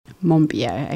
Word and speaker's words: Monbiot: Monbiot, okay.